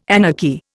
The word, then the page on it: anarchy: Indian